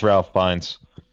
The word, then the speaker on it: Fiennes: It's pronounced Ralph Fiennes. – I'm sorry. I've never heard anybody pronounce his name before. – I have. It's Fiennes.